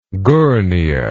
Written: Gournia

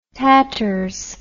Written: tatters

